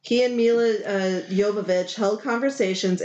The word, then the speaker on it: Jovovich: I found out her last name is actually pronounced Jovovich. – Jovovich. I was close. Yeah. – No, you weren't.